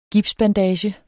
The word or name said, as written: gipsbandage